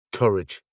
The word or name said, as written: courage